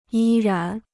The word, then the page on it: 依然: yī rán